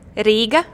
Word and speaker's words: Riga: And you say Riga, Latvia. How do you say it properly? – Riga, Latvia. – Yeah, I definitely, that's not what I sounded like.